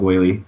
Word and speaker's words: Whaley: But then I think I saw something this week where I think you say his name, Doug Whaley, right? – Yeah.